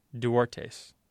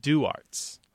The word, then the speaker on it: Duartes: And it's owned by a family who spells their name D-U-A-R-T-E-S. But it is not pronounced Duartes. – It's pronounced Duartes. – Oh, interesting. – Because they've been here so long that the family's completely lost, like, that Spanish pronunciation of the name. It's now just Duartes and not Duartes.